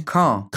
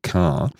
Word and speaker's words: Caen: Tja, und so geht es dann auch immer weiter dieser Woche in der französischen Stadt Sion. Ich hoffe, sie wird so ausgesprochen. – Nee. – Nee? – Caen, Entschuldigung, Caen.